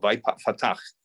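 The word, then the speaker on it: vayiftach: So we have a comment on Rashi on the word vaypatach, which I mispronounced before, vaypatach, and he opened.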